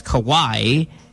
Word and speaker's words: Kawhi: Well, it's spelled Kawhi, but it's Kawhi. And if you're a fan, you would know that.